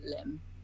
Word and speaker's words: Lim: It's Iona, and then M, and then Acro, and then Lim, because my name is Iona Lim. Like, my surname is like a limb, like arms and legs.